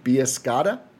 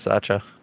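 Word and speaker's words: Bisaccia: Biascotta? – I think it's Biasacha. – Biasacha. That makes a lot more sense. Apparently, he liked Gafford, though.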